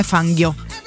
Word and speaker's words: Fangio: comunque non è fangio ma è fanghio Fanghio.